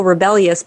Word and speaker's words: Rebellyous: You can just Google Rebellyous, but it's spelled R-E-B-E-L-L-Y-O-U-S.